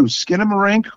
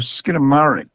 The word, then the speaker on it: Skinamarink: Well, it's going by one of two, Skinamarink or Skinamarink. – Okay. – Either one is acceptable.